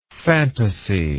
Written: phantasy

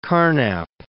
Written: Carnap

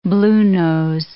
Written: bluenose

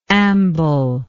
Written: amblingly

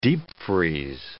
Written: Deepfreeze

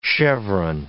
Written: chevron

